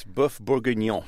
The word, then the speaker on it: bœuf bourguignon: And, of course, the episode was about beef bourguignon. And I may be butchering the French pronunciation here. How do you say it? – It's bœuf bourguignon.